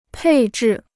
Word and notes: 配置: pèi zhì

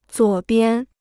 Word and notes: 左边: zuǒ bian

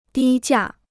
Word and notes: 低价: dī jià

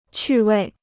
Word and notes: 趣味: qù wèi

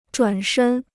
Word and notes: 转身: zhuǎn shēn

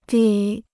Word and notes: 得: de

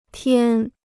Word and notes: 天: tiān

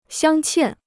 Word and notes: 镶嵌: xiāng qiàn

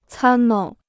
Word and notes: 参谋: cān móu

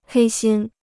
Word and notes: 黑心: hēi xīn

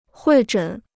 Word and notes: 会诊: huì zhěn

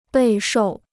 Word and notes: 备受: bèi shòu

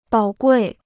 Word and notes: 宝贵: bǎo guì

